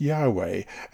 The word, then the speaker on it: Yahweh: When God first entered into a covenant relationship with Abram, he made himself known to the patriarch under the name Yahweh. That's capital Y, capital H, capital W, capital H, YHWH, pronounced Yahweh,